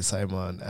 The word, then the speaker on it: Simon: If you just go to LinkedIn and search me up, Simon Njoroge, you'll see me. – Mm-hmm. Could you spell that for those who are not Kikuyu? – Okay, Simon, S-I-M-O-N, mm-hmm. and then last name, Njoroge, N-J-O-R-O-G-E.